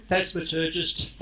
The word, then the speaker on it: phasmaturgist: but phasmaturgist. Did I just murder that word? – No, phasmaturgist, phasmaturgy. I made it up, basically. – Did you? – The first part, Phasma, is ghosts.